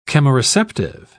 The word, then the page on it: chemoreceptive: British English